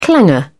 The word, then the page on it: clanger: British English